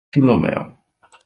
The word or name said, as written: philomel